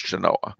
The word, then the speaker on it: Genoa: Spelt like Genoa, but it's pronounced Genoa.